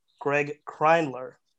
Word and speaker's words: Graig Kreindler: I'm your host and I'm joined by a phenomenal guest today, Greig Kreindler.